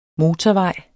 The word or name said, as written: motorvej